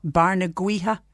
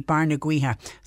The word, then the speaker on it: Barnagaoithe: It does say via Bantry Cross / Barnagwiha, I think it's pronounced B-A-R-N-A-G-A-O-I-T-H-E, Barnagwiha.